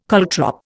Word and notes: caltrop: Indian

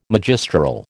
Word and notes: magistral: American English

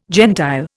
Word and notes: gentile: Indian

